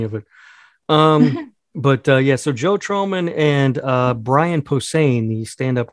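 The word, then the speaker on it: Joe Trohman: They announced a new collaboration on a new comic book series, which has been co-created by, and you probably know how to say this guy's name. Is it Joe Troman? He's the Fall Out Boy guitarist. – Oh, yeah. Yeah, you're right. Joe Troman.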